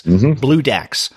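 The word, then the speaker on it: Bloodax: Now, if you're wondering what the name refers to, and it, by the way, it's spelled B-L-O-O-DAX, D-A-X, Blue Dax.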